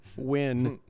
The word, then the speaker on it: Nguyen: N-G-U-Y-E-N. That's pronounced Win. I don't know what I used to say Nguyen for a long time until somebody finally corrected me. So now I know it's Win.